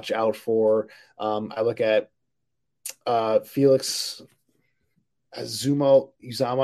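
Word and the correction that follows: Anudike-Uzomah: Let me get the name right here because he's a guy that, yeah, Felix Anudike Uzama um, from Kansas State.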